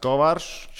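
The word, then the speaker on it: Kovář: Uh, numur divi - Kovārš. Kovār rakstās. Mm -hmm. Izrunājās Kovārš, Čeks.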